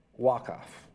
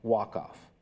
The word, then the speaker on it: Waqf: Waqf. I think I'm pronouncing that right. It's W-A-Q-F. So Waqf, that's how I'm saying it. And that's how I think that's supposed to be said.